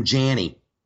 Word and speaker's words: Janney: We got Sam Janney, rhymes with Fanny.